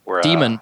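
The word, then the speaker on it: Daemon: I think there's like a virtual world, like in Damon. – Demon. It's called Demon. Yeah.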